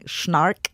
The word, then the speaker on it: Schnarch: David Schnark heißt der Mann, wird lustigerweise, ironischerweise Schnarch geschrieben. Ist aber US-Amerikaner und deswegen Schnark ausgesprochen. Einmal die Psychologie sexueller Leidenschaft und einmal Intimität und Verlangen, sexuelle Leidenschaft in dauerhaften Beziehungen.